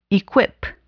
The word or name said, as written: equip